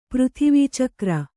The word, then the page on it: ಪೃಥಿವೀಚಕ್ರ: kannada